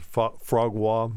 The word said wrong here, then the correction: foie gras: Yeah, foie gras.